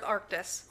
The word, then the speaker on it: Arctis: It's A-R-C-T-I-S. – Darth Arctis. – Arctis.